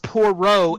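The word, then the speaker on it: Poirot: It's pronounced Poro, and he solves mysteries on trains.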